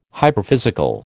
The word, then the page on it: hyperphysical: American English